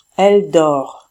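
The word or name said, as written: elle dort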